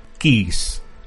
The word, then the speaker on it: Keyes: Con su jefe, que es Keys, se escribe Keyes, o sea, llaves, ¿Mm?